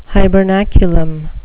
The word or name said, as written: hibernaculum